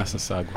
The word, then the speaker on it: Massasauga: You could measure the eastern Massasauga. Am I saying that right? – Yeah, Massasauga.